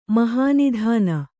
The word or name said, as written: ಮಹಾನಿಧಾನ